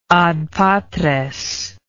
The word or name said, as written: ad patres